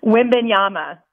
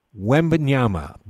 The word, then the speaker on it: Wembanyama: Wembanyama. – Wembanyama.